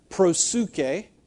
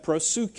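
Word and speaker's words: prosuke (προσευχή): The word is, and it's the Greek word, prosuke, is how you pronounce that, prosuke.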